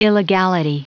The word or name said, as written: illegality